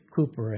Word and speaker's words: Cowper: C-O-W-P-E-R. It must be it's an English name. And Steve told me this morning, you pronounce it Cooper, and it's C-O-W-P-E-R. I say Cowper, but it's Cooper.